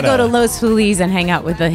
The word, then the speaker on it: Los Feliz: And now there's a sway back towards Los Feliz because it is more like the Spanish pronunciation.